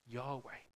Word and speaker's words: Yahweh: Kind of the closest translation we've got in this in Hebrew is YHYH, or pronounced Yahweh.